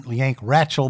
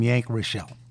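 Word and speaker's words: Yank Rachell: I've heard the name pronounced Yank Rachel, but the people who knew him called him Yank Rachel.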